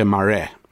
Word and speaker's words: Marais: And it's by a South African author called Eugene Marais, pronounced in South Africa, Marais.